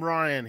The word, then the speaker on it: Rian: Abrams and Rian, is it Rian? How do you pronounce that? Rian Johnson? – We call him Ryan here, so.